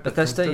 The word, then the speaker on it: Bethesda: Am I saying that right? – Bethesda, yeah.